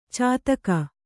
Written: ಚಾತಕಿ

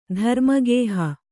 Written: ಧರ್ಮಗೇಹ

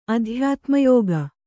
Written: ಅಧ್ಯಾತ್ಮಯೋಗ

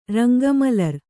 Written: ರಂಗಮಲರ್

